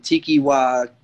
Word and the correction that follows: Taika Waititi: Taika Waititi.